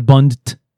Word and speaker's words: bundt: Do you like how the T at the end is like overpronounced? – Yes. – Because it's not a bund. It's right. A bundt cake.